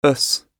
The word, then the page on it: us: British English